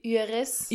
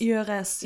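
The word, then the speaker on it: Hyères: Hier ist der Ort nochmal Üres. Üres, ja. Üres, also H-Y-R. E. Ihre, ja. ja. Also H, das H schreibt man auf jeden Fall nicht, äh, spricht, spricht man nicht, mit.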